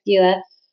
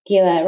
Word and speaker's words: Gila: And he could not believe the amount of time I spent going, Gila, it rhymes with Sheila. Yeah, it's Gila.